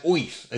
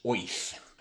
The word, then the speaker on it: Oerth: It's spelled O-E-R-T-H. Gygax says, say it as Oif, as if you were from Brooklyn. That's the way I pronounce it. That annoys all who take a fantasy world far too seriously. So the world of Greyhawk is pronounced Oif.